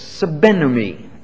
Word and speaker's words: sbennumi: Now, the biblical word for firefighting is a verb, and it's pronounced something like this, sabenumi. It's spelled in English letters, S-B-E-N-N-U-M-I.